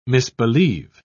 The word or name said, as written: misbelieve